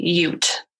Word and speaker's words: Ute: So in the episode, I pronounced it as Ute, and it's actually Ute. – Oh. – Like Utah.